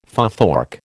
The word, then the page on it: futhorc: American English